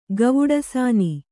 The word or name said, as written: ಗವುಡಸಾನಿ